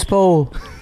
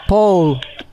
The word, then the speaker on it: Paul: My name is Paul. – Cole? – Paul. Paul. – Can you spell it for me? – P as in Peter, A as in Atom, U and L as in Lucy. Paul.